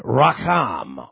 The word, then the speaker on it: racham: Tender mercy is also a term David uses in the Psalms frequently, which is the Hebrew word racham, R-A-C-H-A-M. That's how you have to pronounce Hebrew. You have to do it gutturally.